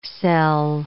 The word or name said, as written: cell